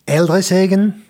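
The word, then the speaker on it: Ældre Sagen: c'est Aldre Sagen, ça va, je l'ai bien prononcé Non. c'est comment alors Alors, maintenant, il faut prendre l'accent danois. Alors, en danois, ça donnerait Eldresegen.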